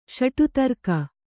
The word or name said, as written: ಷಟುತರ್ಕ